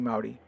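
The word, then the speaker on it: Māori: I think I'm mispronouncing that, but I know it's Maori because I got yelled at by a Maori New Zealander, and it's like that. You say Maori.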